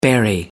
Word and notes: bury: American English

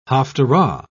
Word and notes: haftarah: British English

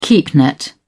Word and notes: keepnet: British English

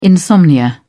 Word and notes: insomnia: British English

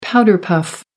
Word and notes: powder-puff: American English

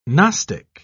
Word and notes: gnostic: British English

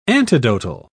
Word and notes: antidotal: British English

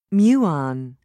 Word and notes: muon: British English

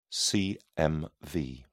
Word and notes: cmv: British English

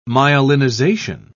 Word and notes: myelinization: British English

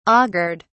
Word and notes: augured: British English

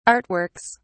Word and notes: artworks: British English

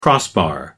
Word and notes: crossbar: American English

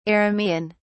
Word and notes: Aramaean: British English

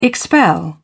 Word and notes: expel: British English

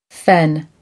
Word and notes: fen: American English

